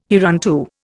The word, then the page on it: hereunto: Indian